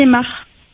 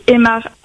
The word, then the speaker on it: Aymard: Anne-Marie Aymar. – Anne-Marie? – Anne-Marie Aymar, A-N-N-E, Marie M-A-R-I-E, Aymar A-Y, M-A-R-D.